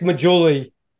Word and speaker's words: Maggiulli: Do Nick Majul, how do you pronounce his last name? I never could pronounce it. He looks Majuli? Like Bobby De Niro. Nick Majuli.